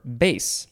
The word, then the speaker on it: Beth: Now we have the word, well, it looks like the word Beth, B-E-T-H, but it is pronounced Baith, or Bait, or Base.